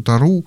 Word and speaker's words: Тору: это вы можете сами почитать Тору, если говорить на еврейский манер, то Тору, надо ударение делать на последнем слоге.